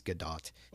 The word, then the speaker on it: Gadot: How do we feel about Gal Gadot? I believe it is Gadot. I've heard people call her Gadot, but I, I think the Israeli proper Israeli pronunciation is Gadot.